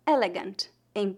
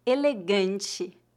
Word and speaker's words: elegante: Aqui temos a pronúncia do anasal, ante. Como muitas palavras da nossa lista, a sílaba tônica é a penúltima e as letras TE no final são pronunciadas como ti, elegante.